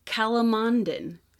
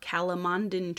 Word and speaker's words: calamondin: When it comes to oranges, you can look for, I apologize, I don't know if I'm going to pronounce this correctly, calamondin, C-A-L-A, cala, M-O-N, mon, D-I-N, din, calamondin trees.